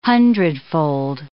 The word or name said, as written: hundredfold